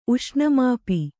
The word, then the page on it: ಉಷ್ಣಮಾಪಿ: Kannada